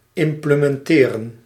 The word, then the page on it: выполнять: /vɨpɐlˈnʲætʲ/